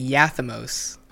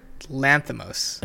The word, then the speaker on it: Lanthimos: I've been saying Yathamos, and his name is Lanthimos. Yorgos Lanthimos.